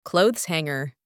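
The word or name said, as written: clothes hanger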